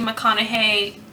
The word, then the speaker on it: McConaughey: But it's possible that Matthew McConaughey, Yep.